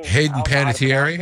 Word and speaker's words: Hayden Panettiere: And I don't know who looked more uncomfortable, Klitschko or his wife, Hayden Panettiere, whatever you say her name. – Hayden Panettiere, yeah.